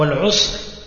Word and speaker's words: Al-Usr: Donc Al-Yusr bil-ya, ou Al-Usr bil-ayn. Et le reste, c'est la même prononciation.